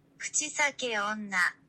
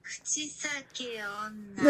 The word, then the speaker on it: Kuchisake onna: Kuchisake onna. – Yeah, I was fucking miles off. You were so off! – Kuchisake onna.